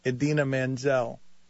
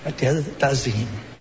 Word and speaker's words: Idina Menzel: For mispronouncing Idina Menzel. – Please welcome the wickedly talented one and only Adele Dazeem.